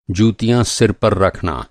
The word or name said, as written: जूती